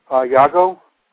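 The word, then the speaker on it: Jago: Iago, with a J, but pronounced with a Y.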